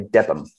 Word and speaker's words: Debenham: It's spelt Debenham, but I think the locals say Debham.